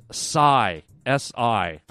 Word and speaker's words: Psy: She wrote in and said it's pronounced Psy, S I. I had it as P S Y, Psy, silent P, silent S, silent Y, if that's possible. Psy is pronounced S-I, Psy.